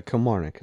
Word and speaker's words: Kilmarnock: Yeah, Kilmarnock is how you say Kilmarnock. – It. Yep. Where's my fucking picture? Oh, here we go. – It's like Norfolk.